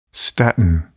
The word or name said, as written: statin